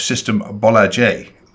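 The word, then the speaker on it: Systembolaget: And then just yesterday, I had a really good chat with Anne Carlson, who's the CEO of System Bollager, that I can never pronounce it very well. She was very kind and told me I got it right.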